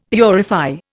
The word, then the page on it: purify: Indian